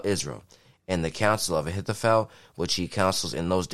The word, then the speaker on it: Ahithophel: Ahithophel.